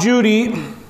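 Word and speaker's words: Cudi: Now it's Mount Judy with a C, okay? Because that's the way they pronounce it.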